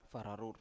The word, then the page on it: fararur: Biak